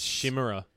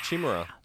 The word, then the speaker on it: Chimera: I always used to thought it was Shimmera. – Oh, really? – Well, that shimmers in this world is sure to fade away again. – It's Chimera. That's Chimera. – How you say it.